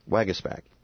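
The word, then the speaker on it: Waguespack: And then at 9:30, Scott Wagesback, and that's how you pronounce it, by the way, Wagesback, from the 32nd Ward.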